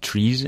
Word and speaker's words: TRIZ: So Triz, actually, it's often pronounced trees in the U.S.